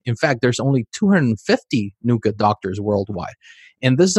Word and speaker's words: NUCCA: NUCA, is that how you say it? – Yep, NUCA.